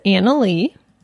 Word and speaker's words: Anali: And his girlfriend is Anna Lee. – Yes. – Spelled A-N-A-L-I.